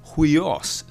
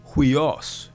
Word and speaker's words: huios: UI is hard to say. Stretch it out, ui. Now say it faster, as in huios, sun, huios.